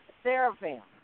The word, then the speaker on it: Seraphim: Seraphim.